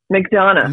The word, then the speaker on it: McDonough: Is it McDonough? McDonough? – McDonough. – Neil McDonough. Neil McDonough.